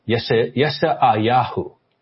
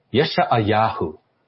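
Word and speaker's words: Yeshayahu (ישעיהו): And I know some of you pronounce differently than I do, but the Hebrew is Yeshayahu. Yeshayahu.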